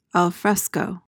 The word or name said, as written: alfresco